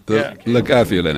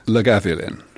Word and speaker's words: Lagavulin: Lagavulin, it's pronounced. – How do you say it? – Lagavulin.